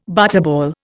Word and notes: butterball: Indian

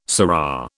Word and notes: surra: British English

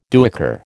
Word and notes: duyker: American English

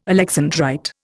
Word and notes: alexandrite: Indian

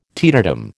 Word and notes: teetotum: American English

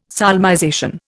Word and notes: solmization: Indian

